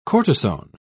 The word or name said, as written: cortisone